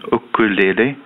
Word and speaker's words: ukulele: En het is ook eh, het dichtst bij de Hawaïaanse uitspraak. Het is een leenwoord uit het Hawaïaans. Aha. En daar zegt men ukulele.